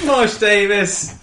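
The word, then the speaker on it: Marsh Davies: It's Marsh Davis. – How do you spell that? – D-A-V-I-E-S.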